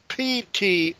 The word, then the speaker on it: Ptolemy: He engaged King Ptolemy, that's Ptolemy, the P is silent, King Ptolemy of Egypt in battle, and Ptolemy turned and fled before him, and many were wounded and fell.